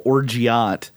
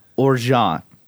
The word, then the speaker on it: orgeat: And Adam says what I call orgeat is pronounced orgeat and is a almond simple syrup, not a liqueur.